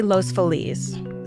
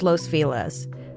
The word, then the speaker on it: Los Feliz: As you heard, I say Los Feliz. Stacy says Los Feliz. Both ways are correct.